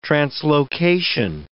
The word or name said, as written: translocation